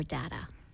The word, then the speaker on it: data: Do you say data or data? – I mean, sometimes data, sometimes data.